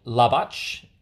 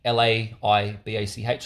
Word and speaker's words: Laibach: I'm probably saying it wrong. Labach. Laibach.